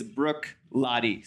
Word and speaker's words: Bruichladdich: But just for our purposes, it is Bruichladdich. So this first CH is kind of like a K, and the second CH is silent. And Bruichladdich is a single malt distillery on the island of Islay, I-S-L-A-Y, Islay.